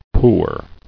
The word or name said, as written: Poorness